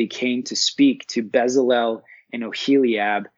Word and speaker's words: Bezalel: I think we would pronounce it Bezalel. Is that correct? – Yeah, yes, absolutely.